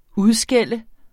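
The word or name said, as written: UDSKÆLD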